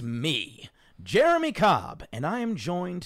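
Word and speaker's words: J'er'em'ih: In reference to Jeremy, spelt J apostrophe E R apostrophe E M apostrophe I H. – Uh-huh. – Pronounced like Jeremy from Dimension 20's side quest, Escape from the Blood Keep.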